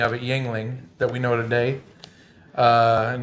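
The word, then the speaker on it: Jüngling: in 1829 by David Gottlieb Jungling, pronounced Yuengling, from Aldingen near Stuttgart, Germany, which I thought was kind of cool since we have a Stuttgart, Arkansas.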